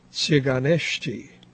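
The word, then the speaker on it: Țigănești: The T, the initial T, which means that is supposed to be pronounced as TS. So it's really Tsiganeshti.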